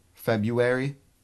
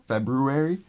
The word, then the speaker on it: February: I always say it like just regular... February. Because February. – It makes it like... – February. – Fucks with my mind if I say the extra R.